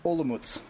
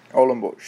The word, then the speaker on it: Olomouc: Olomouc. – Olomouc.